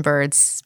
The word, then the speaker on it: Byrd: So my name is Caitlin Bird. It's Caitlin with C's and I's and Bird with a Y. C-A-I-T-L-I-N, Bird, B-Y-R-D.